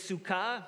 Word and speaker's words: Sukkah: I believe the right pronunciation for our Jewish friends is Sukkot, and the word for tent is Sukkah.